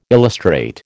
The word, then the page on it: illustrate: American English